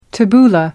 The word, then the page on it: tabbouleh: American English